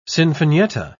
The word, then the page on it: sinfonietta: British English